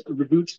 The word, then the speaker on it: Roboute: uh, I think he said some people like to pronounce it as Roboute Guilliman. – Okay, kind of uh the French dialect there going on.